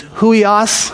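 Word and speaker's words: huios (υἱός): And this is pronounced, it's an H, but it's huios.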